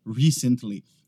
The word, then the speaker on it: recently: Essa aqui não muda tanto, seja inglês americano ou britânico, vai ser sempre recently. O que acontece é que às vezes algumas pessoas pronunciam ela recently. Eles acabam colocando a ênfase no lugar errado. É na primeira sílaba. Recently.